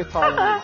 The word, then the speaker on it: Taremi: Because I want to talk about Mahedi Taremi. – Am I saying it right? Yes, yes. Hadi Taremi.